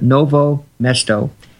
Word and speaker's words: Novo Mesto: I don't know how to pronounce that, but Novo Mesto. – Exactly.